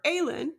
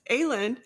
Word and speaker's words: Aelin: So he shares with him that Selena, or Aelin, right? I said that right? Yes. Aelin is in fact still alive,